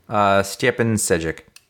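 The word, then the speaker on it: Stjepan Šejić: Stjepan Sejic.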